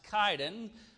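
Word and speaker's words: Chidon: And as they went along, we see at the threshing floor, of the best I can tell you, Kidon is how I pronounce it, is C-H, but that is pronounced Kidon, according to my pronunciation, Kidon.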